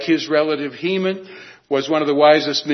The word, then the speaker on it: Heman: Heman, not the Masters of the Universe character He-Man, but Heman, spelled the same way as He-Man.